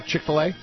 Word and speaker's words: Chick-fil-A: Chick-fil-A.